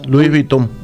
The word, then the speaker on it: Louis Vuitton: ¿Cómo se dice? – Louis Vuitton. – ¿Vuitton se dice? Vuitton. Sí.